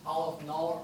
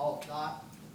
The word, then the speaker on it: Aleph null: So it's pronounced Aleph null or Aleph not.